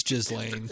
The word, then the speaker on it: Ghislaine: It's Ghislaine. – It's Ghislaine.